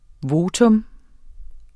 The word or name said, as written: VOTA